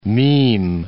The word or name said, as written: meme